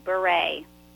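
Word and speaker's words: Bure: Did I pronounce your last name correct as Beret or is it Beret? – You did, Beret.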